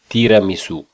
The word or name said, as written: Tiramisu